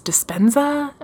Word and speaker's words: Dispenza: I always say his name wrong. It's Dispenza.